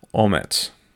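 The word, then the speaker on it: omets (אומץ): Now, the Hebrew word for courage is pronounced omets, while the English word courage comes from the Latin word cor, meaning heart, so that courage might also be called heartedness.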